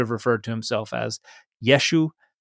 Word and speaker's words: Yeshu: And if the Galileans didn't pronounce that glottal stop or pronounced it poorly, it would have just been Yeshu.